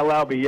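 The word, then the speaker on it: Laube: Lauby rhymes with lousy, by the way. – Yeah, Lauby, yeah.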